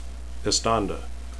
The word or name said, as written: estanda